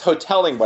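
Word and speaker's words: Hotelling: Oh, it's Hodling, by the way. I thought it was Hodling too.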